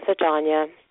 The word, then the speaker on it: Ajanya: My name is Ajanya. – Hi, Ajanya. Did I say that right? Ajanya? – You did. Perfect. Rhymes with lasagna.